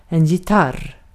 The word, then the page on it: gitarr: /jɪˈtar/